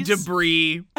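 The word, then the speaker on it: De Bris: Debris. – As in trash. – Yeah. – Oh, that's great. – Because it's spelled D-E, capital B-R-I-S.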